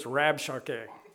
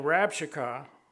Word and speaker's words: Rabshakeh: Doesn't look like you pronounce it that way, but it says Rabshakeh.